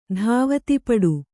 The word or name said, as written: ಧಾವತಿಪಡು